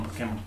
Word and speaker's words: Pokémon: Bueno, Pokémon, Pokémon. – ¿Por qué? ¿Por qué? – Por el acento. Porque tenía acento en la E.